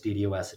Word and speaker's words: DDoS: But I think I read at least one or two places where they guarantee they're looking out for the DDoS attack. Am I saying that right? – They guard against DDoS attacks. Yes, that's done at the router level.